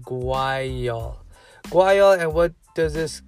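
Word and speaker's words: guaiol: This is the terpene guayol, pronounced guayol. I said it right, guayol,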